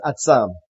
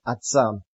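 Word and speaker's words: atzam (עצם): In Hebrew, the word for bone or bones is atzam. If you know Hebrew, it's spelled ayin zadeh mem, atzam.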